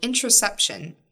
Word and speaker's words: interoception: So interoception. Am I saying it correctly? – Yes.